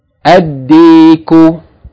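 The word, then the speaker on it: ad-diku (الديك): The second word, ad-diku, ad-diku, a sun letter, meaning the rooster.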